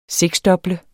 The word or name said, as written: SEKSDOBLE